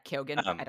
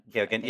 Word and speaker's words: Keoghan: That it just Barry, we're talking about Barry Keoghan, or how do you say his name? – Yeah, Keoghan. – Keoghan. Yeah, I've liked him for some time.